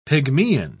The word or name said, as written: pygmaean